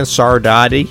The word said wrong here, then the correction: Saudade: Sadaji. – Sadaji, okay.